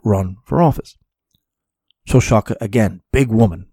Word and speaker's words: Șoșoacă: Same thing goes for Diana Șoșoacă, which is actually how you say her name, which I had no idea.